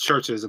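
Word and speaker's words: CHVRCHES: Churches, by the way, is spelled with a V. – Yeah, is that actually Churches? – Yeah, no, I'm pretty sure it's just Churches, and they spelled it like that so people can like Google it and not get like just Churches.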